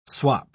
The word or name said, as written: swap